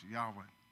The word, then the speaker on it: Yahweh: And one of them is pronounced Elohim, and the other one is pronounced Yahweh.